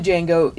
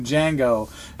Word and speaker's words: Django: And now, that means the exchange between him about the name, when he asked him to spell the name Django, and he says the D is silent, it's one Django to another.